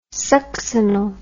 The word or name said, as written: succinyl